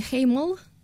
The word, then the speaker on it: Хеймель: Хеймель? І чи правильно я її вимовляю? – Ну, краще, ніж багато людей. – Це тому, що я... – Краще, ніж багато учасників нашої групи. Насправді, Хеймель, правильно, це голландське слово.